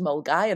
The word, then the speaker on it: Milngavie: And then we have Milngavie, which is pronounced Mulgai.